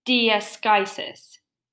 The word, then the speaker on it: diaschisis: Discoides. D-I-A-S-C-H-I-S-I-S. – Oh, like discoides. Discoides. Exactly how you say it.